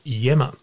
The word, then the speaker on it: Iemma: Then they picked a leader who's a loving man, Morris Yimmer, is the new premier, whose name is spelt I-E-M-M-A. It's an Italian surname, and I think with a Greek background, I-E-M-M-A, pronounced Yemma.